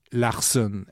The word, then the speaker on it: Larson: Un Américain, cette fois traduit en français, Gary Larson, exactement comme ça se prononce.